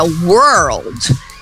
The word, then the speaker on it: world: A palavra world, ela quase que você pode falar que ela tem 2 sílabas. Lá na chave da pronúncia, ela tem 1 sílaba só. Mas observe quando o americano fala que ele acrescenta uma vogalzinha depois do R para apoiar, né? Apoiar a língua, vamos dizer assim, naquela vogal. Então, por que que essa palavra é considerada tão difícil pelo estrangeiro e de maneira geral?